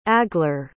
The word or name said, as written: agler